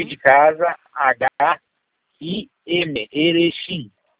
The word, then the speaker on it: Erechim: Erechim, Erechim, Erechim, como se fala?